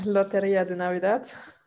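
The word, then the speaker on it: Lotería de Navidad: Äh, Loteria de Navidad. Du kannst bestimmt besser aussprechen als ich. Nein, du hast richtig schön ausgesprochen.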